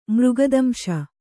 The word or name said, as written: ಮೃಗದಂಶ